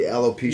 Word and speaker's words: alopecia: It's alopecia. Is that how you pronounce it? The alopecia yes.